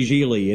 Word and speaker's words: Gigli: Oh, and his character's name is Larry Gigli, and it rhymes with really.